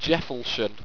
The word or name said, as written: jefltion